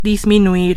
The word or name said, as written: disminuir